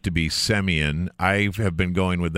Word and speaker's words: Semien: So the takeaway from that is that Boras pronounces it Semien, and that he's worse with the dad jokes than I am.